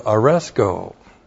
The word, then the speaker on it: aresko: First of all, there is the verb aresko. A-R-E-S-K, long O. Aresko.